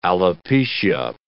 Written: alopecia

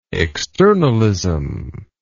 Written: externalist

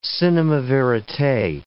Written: cinema verite

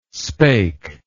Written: spake

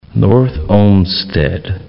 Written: North Olmsted

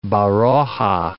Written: Baroja

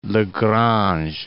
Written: Lagrange

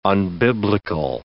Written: unbiblical

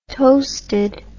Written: toasted

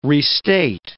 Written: restate

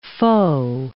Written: foe

